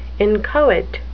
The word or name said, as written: inchoate